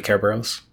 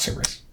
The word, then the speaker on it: Kerberos: Really, it should like the Greek would pronunciation would be Kerberos. So, mm-hmm. – but yeah, we call it Cerberus.